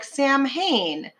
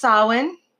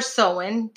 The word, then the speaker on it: Samhain: Most people are, you see it and you're like, Samhain. It's pronounced Samhain or Samhain, depending on who you talk to.